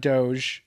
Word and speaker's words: doge: Huddle that doge. Don't pronounce it doja like Mark Cuban and Ellen did.